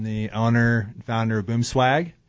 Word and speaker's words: Boomswag: It is not Bloomswag, it is Boomswag Blinds.